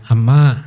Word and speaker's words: amma: Apabila beberapa perempuan bertanya kepada perempuan yang lain, ama a itu pronunciasinya ama ya, diadopsi oleh Indonesia jadi ma ya. Memang gitu bahasa palinya ama, gitu. Ya. A M M A tapi bukan E M A K. Tapi cara membacanya juga gitu ama, gitu.